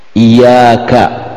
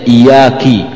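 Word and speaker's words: iyyāka: Tapi kalau dia merubah harakat, iyaka, bacanya iyaki, nah, itu salah artinya, enggak boleh, harus diperbaiki oleh makmum.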